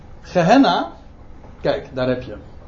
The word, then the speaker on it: Gehenna: Gehenna is gewoon, komt van het Hebreeuwse Gaihinom. Alleen het is de Griekse uitspraak, zeg maar.